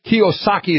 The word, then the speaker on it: Kiyosaki: Kiyosaki is how you say that guy's name.